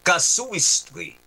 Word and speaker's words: casuistry: Ayun pala yung tama pronunciation. Casuistry. Ayan ha.